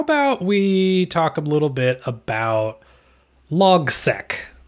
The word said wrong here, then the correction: Logseq: I believe it's pronounced LogSeq.